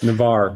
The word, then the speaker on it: Navarre: And you know, have you seen in a market like, it's Navarre, is that how it's pronounced? I've never heard of this place. – Navarre, yeah, sorry.